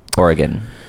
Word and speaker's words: Oregon: Do you pronounce it Oregon or Oregon? – Oregon.